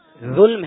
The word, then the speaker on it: ظلم: یہ لفظ ظلم ہے یا ظلم ہے؟ – یہ ظلم ہے ساکن.